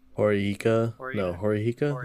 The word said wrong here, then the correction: Horikita: Is that right? – Oh, did I say Susan? – You mean Horikita? – Horikita, thank you.